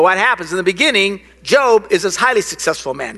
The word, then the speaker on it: Job: The story of Job in the Bible, now it's spelled J-O-B, so to us it looks like job. I can always tell people who are new in church, because I was reading this job thing. And it's not job, it's Job. It's how you say it.